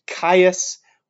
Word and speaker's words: Gaius: and the most powerful of them is named Caius, which is how the name Gaius, or Caesar's first name, would have been pronounced in ancient Rome.